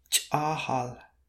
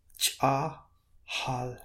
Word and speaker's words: Ch'ahal: Ch'ahal, that has two long A's again. Ch'ahal, and that means frog.